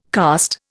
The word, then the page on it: karst: Indian